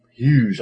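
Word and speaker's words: Huyghe: Huge. I think it actually is pronounced Huge. – It probably is Huge. – It's H-U-Y-G-H-E.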